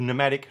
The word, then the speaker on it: pneumatic: And, you know, and so if you buy, if you go into a hardware store and you say, I want a pneumatic drill, they will look at you funny because you don't get pneumatic drills, you get pneumatic drills. The P is silent.